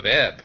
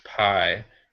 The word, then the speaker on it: Pi: Yeah, and it's definitely one of those titles where it's like Life of Ip. What's that all about? And somebody says it's pronounced Pi, and they're like, oh, that's interesting.